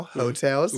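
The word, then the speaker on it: Heaux Tales: Jasmine Sullivan, I know how to pronounce this now, Hotels.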